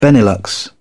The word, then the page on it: Benelux: British English